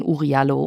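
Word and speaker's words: Oury Jalloh: Wir sagen hier Jallo, obwohl er oft auch Uriallo genannt wird. Warum habt ihr euch für diese Aussprache entschieden? – Ja, wir haben uns für diese Aussprache entschieden, weil wir Interviews haben von der Mutter von Uriallo. Die ist inzwischen gestorben, aber es gibt noch Archivaufnahmen von ihr und sie selber nennt ihren Sohn Uriallo.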